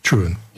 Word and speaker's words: Trewoon: Yeah, but it's saying about Truan. It's spelt Trewoon. Truan.